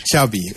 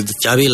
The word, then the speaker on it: Xavi: Xavi. – Okay. Yeah. – So I heard the... Xavi like chocolate. – Yes. Okay. Thank you very much. – Oh, Xavi